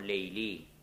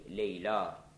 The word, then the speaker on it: Layla: And in Arabic poetry, there is so much reference to Layla or Layli, as it is pronounced Lala or Layli or Lili, mostly Layla in Arabic.